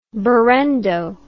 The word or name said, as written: berendo